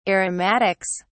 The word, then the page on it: aromatics: British English